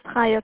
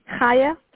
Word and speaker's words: Chaya: My name is Chaya, calling from Family First Home Care. I'm looking for Alicia. – Okay, and what did you say your name was? – Chaya, C-H-A-Y-A. – C-H-A-Y-A.